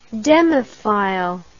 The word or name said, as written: demophile